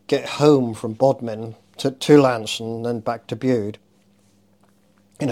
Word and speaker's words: Launceston: I started work for the River Board in what you would pronounce as Launceston. – Yeah. – But it's actually pronounced Lanson.